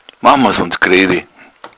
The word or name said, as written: griawig